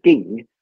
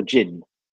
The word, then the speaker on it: Ging: So when you say Ging, is that the Cantonese pronunciation of what... a lot of people call Jin. – Yeah, exactly. – Or mispronounce Jin. – Yeah, it's Cassie's version of Jin, yeah.